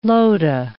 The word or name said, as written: lota